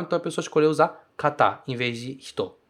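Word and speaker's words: kata (方): Esse kanji que tá aí, ele se pronuncia kata, muitas vezes, para significar pessoa, de uma maneira mais formal.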